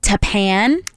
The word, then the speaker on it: Tappan: And we also have Richard in Tappan.